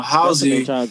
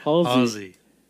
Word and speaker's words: Halsey: So Halsey, she's how you say her name? – Halsey. – Halsey. – Like Hall that you put in your mouth.